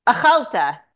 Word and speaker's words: Achalta: Achalta, sorry. The emphasis is on the Al, a nonpartisan think tank, advancing social cohesion and national resilience in Israel.